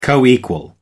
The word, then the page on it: coequal: American English